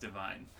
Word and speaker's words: Devine: I know it's pronounced divine, so I'm cool.